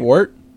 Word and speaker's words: Wort: What's Wurtz? Right? Am I saying that right? – Wort? – Yeah, that's I'm saying, shit.